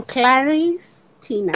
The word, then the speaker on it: Claris Tina: it's okay it's okay to learn i'm claris tina claris tina so it's not clarissa all right that's great.